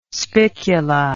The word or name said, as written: spicula